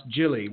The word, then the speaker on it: Gilley: And actually, just while we get started, and this sort of leads into it, my last name is actually pronounced Gilley with a soft French G.